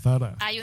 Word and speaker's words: Zara: Y decía que Zara se pronunciaba Sarah.